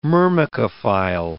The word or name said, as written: myrmecophile